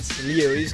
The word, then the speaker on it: Lewis: Lewis o Lewis? – Lewis con la IU.